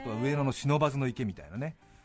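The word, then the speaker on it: 不忍池: 読むときはちゃんと書き下し的に忍ばずの池って読まなきゃいけないみたいで忍ばずの池ってねあの書くときはみんな漢字 3 文字で「ふ」忍池って書いて忍ばずの池って読むんです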